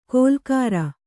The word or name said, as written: ಗೋಲಾಕಾರ